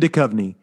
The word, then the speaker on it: Duchovny: Duchovny? – David Duchovny.